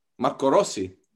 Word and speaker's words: Marco Rossi: How did you say that again? You said that with some twang. – Marco Rossi, Italian. – Oh. Yeah, Marco Rossi. – Marco. – Marco. It's Marco.